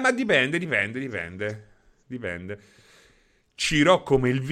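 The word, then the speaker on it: Cirò: ci incontriamo, io sono a Ciron Marina. Ciron Marina. Ciron, ma aspetta, come cazzo? Ciron, ma che lingua è? Ciron? No, Ciro, Ciro, Ciro con l'accento.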